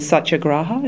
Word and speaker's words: Satyagraha: I know that you've recently made your Met debut as Gandhi, as you mentioned before, in Satyagraha. Is that the right pronunciation? – That's right, yeah.